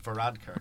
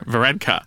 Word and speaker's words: Varadkar: Varadkar. – Varadkar.